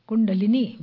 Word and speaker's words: kundalini: Kundalini, but here in this country, kundalini. Like a pasta, linguini. Okay, rhymes with that.